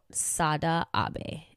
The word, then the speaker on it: Sada Abe: This is a story about Sada Abe, and I did Google the pronunciation of this name. That is how it's pronounced.